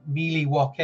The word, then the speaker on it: Milwaukee: Because that was in one of my favorite movies, and it was Alice Cooper, and he said it's actually pronounced Mealy Woke.